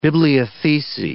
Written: bibliothecae

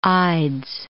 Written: ides

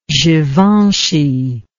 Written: givenchy

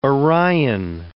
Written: Orion

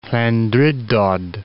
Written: Llandrindod Wells

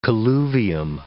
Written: colluvium

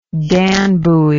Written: danbuoy

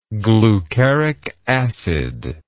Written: glucaric acid

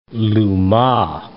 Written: louma

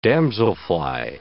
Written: damselfly

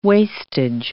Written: wastage

